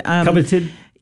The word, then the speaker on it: coveted: So you got the covenanted, how do you say that word? – Coveted.